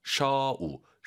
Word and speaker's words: 手里: 그리고 쇼가 아니라 오유는 어우입니다. 쇼우 쇼리 꼭 기억하시고요. 쇼 안 돼요.